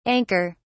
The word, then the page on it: anker: British English